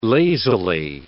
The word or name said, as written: lazily